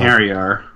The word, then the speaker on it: Einherjar: Series composer Motoi Sakuraba returns to the project too, along with other familiar gameplay elements like the Einherjar. In Valkyrie Profile, Einherjar, by the way.